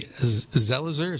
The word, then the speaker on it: Zelizer: Zelizer.